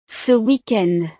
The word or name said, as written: ce weekend